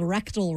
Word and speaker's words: Rekdahl: Oh, rectal, but it's spelled R E K D A H L or something like that. But it's pronounced rectal.